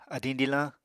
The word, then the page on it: adiñ dilañ: Apatani